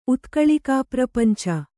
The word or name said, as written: ಉತ್ಕಳಿಕಾಪ್ರಪಂಚ